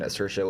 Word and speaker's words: Saoirse: No, it's Saoirse, right? – Saoirse Ronan? Okay. – Yeah. – So she and Greta Gerwig, they first met at the Toronto Film Festival in 2015. Saoirse was promoting Brooklyn, and then Gerwig was promoting Maggie's Plan.